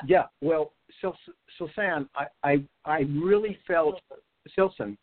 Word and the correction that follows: Filsan: Filson. S. F. – Filson? – Yeah, it's an F, right? – Yeah. – How you spell it? – F-I-L-S-A-N. – Oh, I thought it was S.